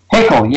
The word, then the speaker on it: Haeckel: Is it Haeckel? – Haeckel, yes.